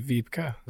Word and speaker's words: Wiebka: What is How do you spell it? – W-I-E-B-K-A. – Yeah, it'd be Vipka. – Vipka. – Yeah. – Her name is Vipka.